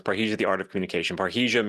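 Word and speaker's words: parrhesia: Is that how you say it? – Yeah, it's parahesia.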